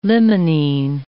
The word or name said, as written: limonene